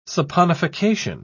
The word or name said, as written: saponification